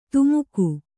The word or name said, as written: ಧಿಮಿಕು